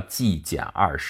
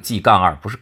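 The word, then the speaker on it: G-2: 这个实验的名字叫 G 减二实验，很多人读成 G 杠二，不是杠二，那个就是减号。减二的意思就是看看这个 G 的数值，实验的数值跟二到底差多少，差的这个部分跟理论预测是不是一致。所以呢，干脆就直接很形象的就是 G 减二实验啊，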